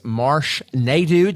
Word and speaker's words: Marsh Naidoo: Our guest today is Marsh Naidoo.